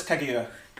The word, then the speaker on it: Kaguya: It's Princess Kaguya. – Princess what? – Kaguya. – Kaguya. Princess K-A-G-U-Y.